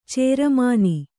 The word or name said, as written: ಚಾರ್ಮಣ